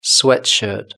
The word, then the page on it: sweatshirt: British English